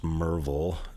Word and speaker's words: Maryville: It's pronounced Merville. If you're from Maryville, you'll know that I'm not lying because I know how to say it right.